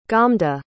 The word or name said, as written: gomda